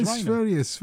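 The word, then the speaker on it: Sverige: No, Sveria is the right, correct pronunciation. Well done, you, Ruby. Yeah, it's Sveria.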